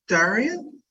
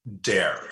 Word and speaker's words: Dare: is it Daria? – Dare, straight. Dare. It's pronounced dare, like I dare you.